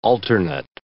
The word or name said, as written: alternate